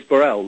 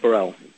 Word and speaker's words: Burrell: My name is Burrell, Lloyd Burrell, B-U-R-R-E-L-L. – B-U-R-R.